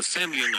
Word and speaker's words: Semlyen: Then there's Nick the Semlyen.